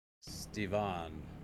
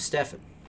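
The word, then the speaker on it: Stephan: But the way that he spells it is spelled like Stephen. It's Stephen with an A. – Stevan. – Yep. Yep. Well, no, it's still P-H. S-T-E-P-H-A-N. His name is Stefan.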